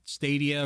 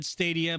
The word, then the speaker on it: Stadia: Well, I've said Stadia, but I've had people correct me and say Stadia. – They say it in their ads. They're like Google Stadia. Like, unless their ads themselves are being mispronounced. No, it's Stadia.